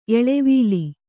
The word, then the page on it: ಎಳೆವಲೆ: kannada